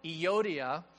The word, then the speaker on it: Euodia: In fact, Paul talks about two ladies in the church, and here's how you pronounce their names. It's Euodia and Sedehi. That's how you actually pronounce these names.